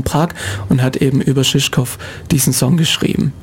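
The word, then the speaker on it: Žižkov: Ja, also der Künstler heißt tatsächlich Valentin Vogt, ist ein Singer-Songwriter hier aus der Gegend und der Titel heißt Shishkov Waltz. – Shishkov Waltz, Shishkov, okay, also komplett falsch ausgesprochen.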